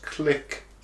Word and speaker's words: clique: Number two, I think it's pronounced click.